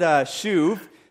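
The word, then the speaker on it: shuv (שׁוּב): So this Hebrew word shuv, so it's pronounced shu with a V on the end, shuv, all right?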